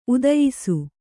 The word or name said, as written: ಉದಯಿಸು